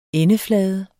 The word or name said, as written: endeflade